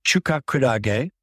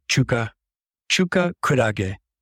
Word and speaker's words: chuka kurage: So chuka kurage is how you pronounce it. Chuka, chuka kurage. The etymology is chuka uh, refers to Chinese style or Chinese origin, and kurage uh, is the word for jellyfish.